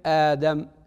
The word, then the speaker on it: آدم: وقد ذكرت قول الإمام الشاطبي رحمه الله في ذلك، وإبدال أخرى الهمزتين لكلهم إذا سكنت عزم كآدم أوهلا، فأصل آدم أأدم، همزتان في أول الكلمة، الأولى مفتوحة والثانية ساكنة، فتقتضي القاعدة في العربية أن تبدل الثانية ألفا فتصبح آدم، همزة يليها ألف.